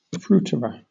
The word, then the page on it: fruiterer: British English